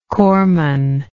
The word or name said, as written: corpsman